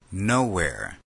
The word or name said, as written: nowhere